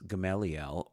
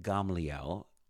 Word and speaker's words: Gamaliel: And his name is Gamaliel, or in Hebrew, we actually pronounce it Gamaliel.